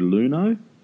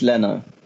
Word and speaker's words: Leno: It's Leno. Leno. I mean, it's a four-letter word. It's really not that hard to pronounce.